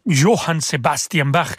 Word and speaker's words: Johann Sebastian Bach: Johann Sebastian Bach, exactement comme on prononce son, son nom en allemand.